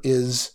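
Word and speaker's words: Is (εἰς): The Greek word used here for the word one that you see, the Greek word used here is spelled E-I-S, ice maybe? Is, which means first or primary.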